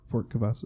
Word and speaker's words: Cavazos: Fort Cavazos.